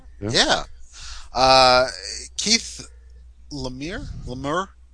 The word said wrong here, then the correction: Laumer: L-A-U-M-E-R. What is it? Retief? – Lamer. Right. Yeah, Keith Lamer. – Right. – I think it's Lamer.